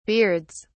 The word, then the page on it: beards: British English